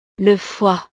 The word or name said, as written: le foie